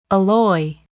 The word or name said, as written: alloy